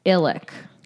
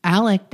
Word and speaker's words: Alex: Also, interesting thing, the patriarch, that's sort of like the living patriarch, Alex Murdaugh, his name is pronounced Illick. – Really? – Well, that's what she said. – Is it spelled Alex? – It's spelled Alex. – And it's pronounced Illick? – Or Alec.